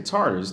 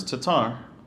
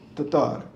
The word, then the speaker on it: Tatar: They say Tartars, Tatar. – Tatar. – Yeah, in English, it's both. They use both.